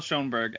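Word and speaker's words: Schönberg: So it's Claude-Michel Schoenberg and Alan Booville.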